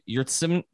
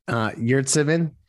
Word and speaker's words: Yurtseven: Of course, the other thing was our friend Omer, I guess it's Omer Yurtsevin. Pronounce it for me. Sorry. – Yurtsevin. – Thank you. Much better than I did.